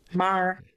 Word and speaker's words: Maher: And the image on the screen is Bill Maher, Maher, whatever, however you pronounce it. – Maher. – Bill Maher. There we go.